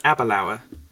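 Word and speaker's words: Aberlour: I think it's pronounced Abelauer.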